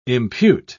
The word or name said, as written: impute